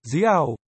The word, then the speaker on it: Dior: Dior là, dí ảo.